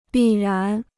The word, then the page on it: 必然: bì rán